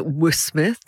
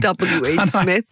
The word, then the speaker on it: W.H. Smith: And I remember I kept pronouncing it W.Smith, not realizing it was like W.H. Smith, I didn't have any excuse.